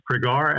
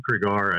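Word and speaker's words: Cregar: And that is that most people think he's Laird Krieger, which is I always thought for years. But yeah, the last name is pronounced Krieger as in cigar, is the way that he used to actually put it.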